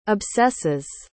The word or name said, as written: obsesses